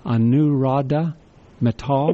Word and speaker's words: Anuradha Mittal: His name is spelled A-N-U-R-A-D-H-A-M-I-T-T-A-L. I believe it's pronounced Anuradha Mittal.